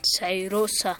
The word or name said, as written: Sayrosa